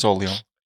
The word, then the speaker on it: Orléans: Which historical figure is sometimes called the Maid of Orleans? – Go on. – Or Orléans. I think it's Orléans. – The Maid of Orléans? – Yes, Orléans. You need to say it like that.